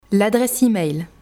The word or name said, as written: l'adresse email